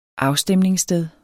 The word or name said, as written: afstemningssted